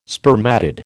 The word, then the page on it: spermatid: American English